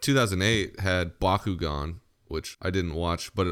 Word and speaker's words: Bakugan: Ben 10 Bakugan fight. You have to say it like that. Bakugan.